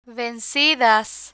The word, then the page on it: vencidas: Spanish